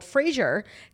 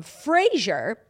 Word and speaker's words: Fraser: So he says, instead of a similar pronunciation similar to the 90s sitcom Frasier, Frasier says his name actually rhymes with razor. So it's Fraser.